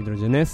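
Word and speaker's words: Hidrogenesse: Es el debut en el programa de una de mis bandas favoritas, Hidrogenés, o Hidrogenés, si prefieren la pronunciación catalana, con Captcha Cha Cha.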